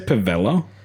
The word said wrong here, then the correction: Pavetta: Did you just say Pavella? Yeah, I said it wrong. – Yeah, come on, man. – What's her name? – Pavetta.